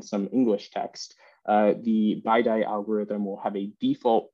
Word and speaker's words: BiDi: And uh, as I understand it, there's quite a lot of contention on how you actually pronounce this, whether it's bidi or biddy or something like that. So, so take your, your choice and how you'd like to pronounce this algorithm. But uh, I'll call it BiDi.